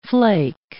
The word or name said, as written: flake